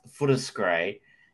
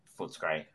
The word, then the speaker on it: Footscray: And I think also, just to note there, the second commercial being the Franco Cazzo is a reason why Footscray is properly pronounced Footscray, not Footscray.